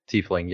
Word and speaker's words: tiefling: Oh, tiefling.